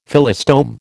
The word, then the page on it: Phyllostome: American English